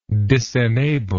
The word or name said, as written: disenablement